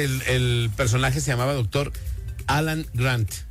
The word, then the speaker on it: Alan Grant: Alan Grant. – Se escuchó hasta la T marcada y todo.